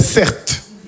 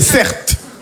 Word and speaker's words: Certe: Je m'appelle Certe. Mon prénom c'est Certe. Ça s'écrit C-E-R-T-E. R-T-E.